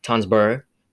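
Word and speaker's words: Tønsberg: The Asgardian warriors defeat the frost giants in Tonsberg, Norway, and seize the source of their power. I totally mispronounced Tonsberg. I'm not supposed to pronounce the R. Tonsberg, Norway, and seize source of their power, the Casket of Ancient Winters.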